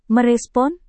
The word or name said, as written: merespon